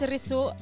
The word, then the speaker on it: Risu: My name's Risu. That's spelled R-I-S-U because people just call me Rushu.